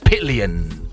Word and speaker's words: pillion: Pillion, okay? Pillion rhymes with billion. Pillion. P-I-L-L-I-O-N.